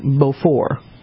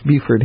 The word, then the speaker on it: Beaufort: Beaufort is not, it's pronounced, it would be pronounced in France, Beaufort, but it's pronounced Beaufort here.